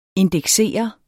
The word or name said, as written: indeksere